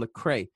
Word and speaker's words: Lecrae: He goes by the name Lecrae.